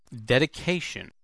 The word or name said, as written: dedication